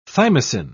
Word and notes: thymosin: British English

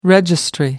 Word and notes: registry: American English

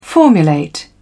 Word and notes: formulate: British English

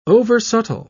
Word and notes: oversubtle: British English

